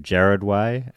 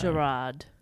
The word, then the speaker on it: Gerard: I was calling him Jared Way, and a few people told me that is not how you pronounce his name. – Gerard.